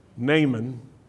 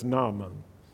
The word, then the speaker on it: Naaman: In southern English, you say Naaman, which rhymes with that guy. But in Hebrew, it also rhymes, but it's Naaman.